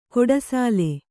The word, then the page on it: ಗುಡಿಸಿಲು: guḍisilu